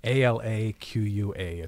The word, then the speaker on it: Alaqua: A L A Q U A.